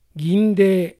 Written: Gindei

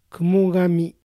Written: Kumogami

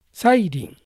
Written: Sairin

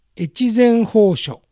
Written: Echizen Hosho

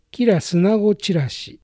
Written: Kirasunagochirashi

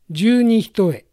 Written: Junihitoe

